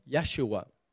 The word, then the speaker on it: Yeshua: Now, in the Hebrew, the word Joshua is pronounced Yeshua.